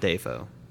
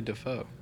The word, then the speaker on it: Dafoe: Although I will say, it like at least the Wikipedia pronunciation of Dafoe is definitely not Dayfo. – Yeah, it's definitely Dafoe.